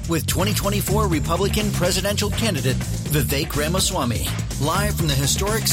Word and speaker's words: Vivek: I've pronounced the name correctly from the get-go. – Right. – But people are confused sometimes by it because of the spelling Vivek. You get a lot. It is Vivek like cake.